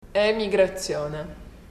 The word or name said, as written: emigrazione